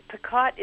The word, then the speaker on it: Paquette: We say Paquette. Other parts of the family say Paquette. So Paquette? How about Paquette? – Actually, it's a French name. – Oh, and Paquette. – Paquette is the correct pronunciation.